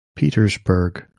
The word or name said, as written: Petersburg